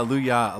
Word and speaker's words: Louyah: So you pronounce it Louyah, huh? – Yeah, Louyah.